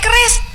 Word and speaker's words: QRIS: Bisa ya bayar pakai kris? Kok malah kayak mau bela diri aja? – Kris, Bu. Kris. Q R I S. Dibacanya kris bukan keris.